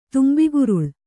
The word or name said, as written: ತುಂಬಿಗುರುಳೆ